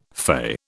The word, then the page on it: fey: British English